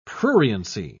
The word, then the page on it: pruriency: British English